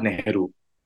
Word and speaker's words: Nehru: Y tienes además, esto es en español por la universidad, ayúdame con la pronunciación de Yabajarla. – Sí, al Nehru.